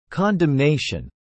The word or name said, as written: condemnation